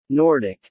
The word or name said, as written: nordic